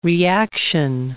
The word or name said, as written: reaction